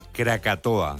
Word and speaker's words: Krakatoa: Krakatoa. – Lo he dicho bien. – Sí, parece euskera, pero es indonesio.